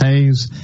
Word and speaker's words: Hayes: It looks like Haas, but it's pronounced Hayes and uh, I will never forget that um, unless I have a, a, a memory lapse.